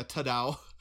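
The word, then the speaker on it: Todd-AO: Yeah, Tadao. – Tadao. Yeah. Is that what it's called? – I think that's how you pronounce it.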